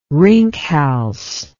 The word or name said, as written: rinkhals